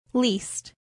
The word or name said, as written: least